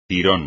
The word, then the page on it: tirón: espagnol